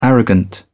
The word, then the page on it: arrogant: British English